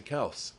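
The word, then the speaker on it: Kelce: Apparently, Travis Kelsey went on a Bleacher Report AMA session, and they were having a conversation, and he was like, yeah, people have been saying me and my brother's name wrong. It's Kels. It's not Kelsey.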